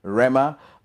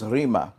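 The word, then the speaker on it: rhema: Okay, and that's Logos is spelled L-O-G-O-S, Logos. Okay, and then the third one is Rima. Okay, it is Rima. We pronounce it Rema, but it is Rima.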